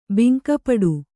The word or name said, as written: ಭಂಗಪಡು